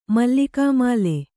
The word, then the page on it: ಮಲ್ಲಿಕಾಮಾಲೆ: mallikāmāle